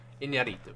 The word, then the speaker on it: Iñárritu: Iñárritu. – Iñárritu. – I-N-Y-A-R-I-T-U.